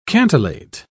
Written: cantillate

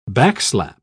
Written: backslap

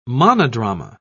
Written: monodrama

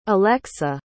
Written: elexa

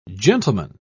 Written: gentleman

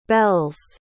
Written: belles

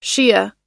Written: Shia

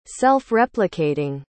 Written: self-replicating

